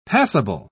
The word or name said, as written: passible